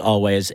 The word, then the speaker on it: Alvvays: but it is A-L-V-V-A-Y-S, pronounced Always, and their song Archie Marry Me.